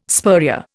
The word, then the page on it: spurrier: Indian